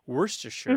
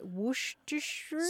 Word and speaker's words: Worcestershire: Worcestershire mm-hmm.